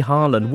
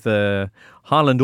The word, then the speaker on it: Haaland: Okay, well, there was loads to get excited about with Haaland or Haaland, as the BT were commenting. So it turns out that's how you pronounce it in Norway.